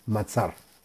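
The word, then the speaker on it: Matzar (מצר): El Salmo 74 se debe meditar con el santo nombre de Mem Tzadik Reish, que se pronuncia Matzar.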